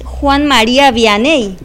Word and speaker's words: Juan María Vianney: si no lo pronuncio mal, es Juan María Vianney. – Exactamente, el cura de Ars.